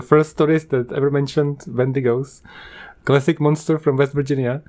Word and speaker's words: Wendigos: And not only that, coming up on Halloween, episode 55, I'm gonna talk about Bendigos. Is that what you call those? – I think it's pronounced Wendigos. Like, you gotta go up at the end or else you don't have the right accent.